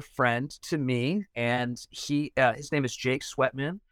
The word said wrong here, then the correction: Sweetman: and Jake Sweetman. – That's on newer friends we are. – Did I say it wrong? – Sweet, not sweat.